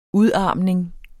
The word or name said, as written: udarmning